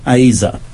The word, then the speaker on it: Ezer: The Hebrew word for helper is Ezer, spelled E-Z-E-R, but pronounced Aza.